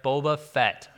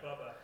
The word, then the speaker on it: Boba Fett: Boba Fett, yeah. Pronounce it right. Bubba. Not Bubba Fett.